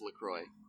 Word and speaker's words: LaCroix: determined via the LaCroix people that it is pronounced LaCroix.